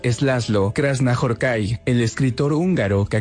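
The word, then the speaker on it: László: Laszlo.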